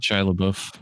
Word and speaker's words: Shia LaBeouf: Oh, and there's a really young Shia LaBeouf, how you pronounce his name, in there too. – Shia LaBeouf.